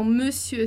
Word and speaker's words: monsieur: E aí, veja que, por conta do uso, acontece que a escrita não tem mais nada a ver com a pronúncia, tá? Então, monsieur, senhor, e a gente fala monsieur, monsieur.